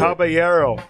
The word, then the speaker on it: Caballero: It's Caballero. You got to respect the Dominicans, man. – Caballero. – Caballero, see? Tell him. – Caballero. – Caballero. – Caballero. – You don't say it right, man. It's Caballero.